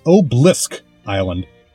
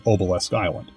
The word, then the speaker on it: Obelisk Island: The island is pronounced in the English dub as Oblisk Island, even though it is clearly spelled Obelisk Island, and I'm assuming it's supposed to be Obelisk Island.